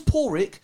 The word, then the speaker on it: Páraic: But because my name's Páraic and it's spelled Paraic,